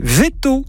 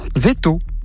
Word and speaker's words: Veytaux: Veto. Alors, ça s'écrit V-E-Y-T-A-U-X. – Veto.